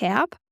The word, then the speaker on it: Haerb: Ich habe dafür mit der liebsten Sophia Kühl von Herb, ähm, schreibt man H-A-E-R-B, aber ausgesprochen wird es Herb, in Berlin gesprochen und Sophia hat mit ihrer Brand eben ihre Leidenschaft für Pflanzenheilkunde zur Berufung gemacht.